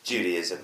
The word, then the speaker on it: Judaism: I've heard it pronounced Judaism and Judaism. How do you pronounce it properly, first of all? – Judaism. – Yeah. – Judaism? – Yeah. – Okay. – Like Judy Dench.